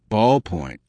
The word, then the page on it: ballpoint: American English